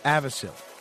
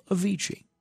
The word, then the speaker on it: Avicii: I never knew that was how it's pronounced. I always thought it was Avicii.